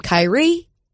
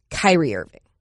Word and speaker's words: Kyrie Irving: And also we should specify, as I do every time Kyrie Irving comes up, only person whose name is pronounced differently if you say their last name or not. You call him Kyrie, But if you're saying Irving, it's Kyrie Irving.